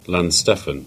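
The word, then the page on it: Launceston: Cornish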